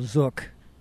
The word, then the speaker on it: Zook: I have to say that your name, Cheryl Zook, rhymes with book, is a pretty cool name.